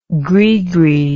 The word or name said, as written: grigri